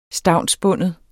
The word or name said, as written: stavnsbinde